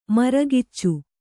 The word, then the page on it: ಮರಕಿಚ್ಚು: Kannada